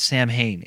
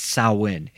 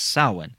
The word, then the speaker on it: Samhain: It's not Samhain, it's Samhain. It's spelt Samhain, but it's pronounced Samhain.